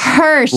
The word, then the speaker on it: Hirsch: It's Hirsch. – Hirsch. – Like Hershey's, like the candy.